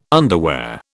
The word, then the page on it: underwear: British English